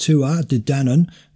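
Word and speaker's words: Tuatha Dé Danann: Well, in 1456 BC, a contingent of the famous Tuatha, pronounced Tua Dé Danann, the tribe of Dan, arrived in Ireland and ruled for 440 years until 1016 BC.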